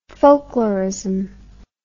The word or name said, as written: folklorism